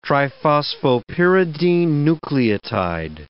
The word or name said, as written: triphosphopyridine nucleotide